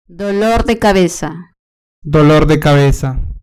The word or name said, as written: dolor de cabeza